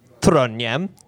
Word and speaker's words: Trondheim: Trondheim.